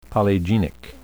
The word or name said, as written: polygenic